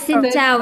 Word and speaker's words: Xin chào: Xin chào? – Yes, it's right. Xin chào.